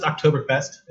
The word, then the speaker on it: Oktoberfest: Uh, just Oktoberfest, I think it's.